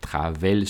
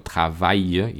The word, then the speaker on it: travail: Por ejemplo, las terminaciones AIL como travail, détail, no se pronuncia E, travel, sino travail y détail.